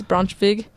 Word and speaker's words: Braunschweig: Specifically, the best I can pronounce it, Braunschweig. I know W's are V's. Braunschweig, Germany. Sorry if I did probably butcher that.